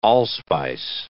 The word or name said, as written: allspice